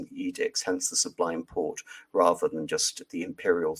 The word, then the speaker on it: Porte: And in 1805, reluctantly, the Ottoman Sublime Porte, again, Sublime Porte, port, literally the French iteration of gate, where the edicts of the Ottoman Sultan are pronounced.